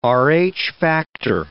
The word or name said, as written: Rh factor